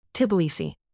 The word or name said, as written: Tbilisi